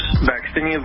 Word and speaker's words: vaccinia: So they have taken a virus, vaccinia virus, is how you say it, and they have spliced in genetic code from Ebola into that virus, and that virus is a live virus vaccine.